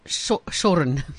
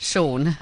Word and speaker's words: Shorne: My plumber is Shoren. S H O R N E. Shoren. We call him Shoren. – How does he pronounce it? – Sean.